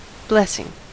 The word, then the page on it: blessing: American English